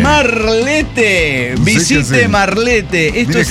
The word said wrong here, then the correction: Marlette: A ver, y se debe pronunciar Marlette. – Marlette.